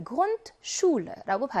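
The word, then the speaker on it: Grundschule: SCH가 들어가 있는 복잡한 조합이니까 여기 나와 있는 SCH는 무슨 발음이에요? SH 발음을 내서 Grundschule라고 발음했습니다.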